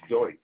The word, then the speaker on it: Deutsch: First name Robert, last name is Deutsch.